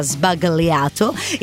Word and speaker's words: sbagliato: Lei dice Negroni sbagliato, tra l'altro con questa pronuncia, sbagliato.